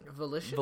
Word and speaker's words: Volition: Volition? Is that how you say it? – Volition.